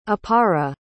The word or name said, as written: apara